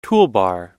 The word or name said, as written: toolbar